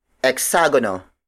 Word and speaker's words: eksagono: The next word is hexagono. Hexagono. Hexagono. Hexagono is spelled as E-K-S-A-G-O-N-O.